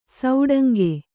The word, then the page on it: ಷಡಂಗ: ṣaḍanga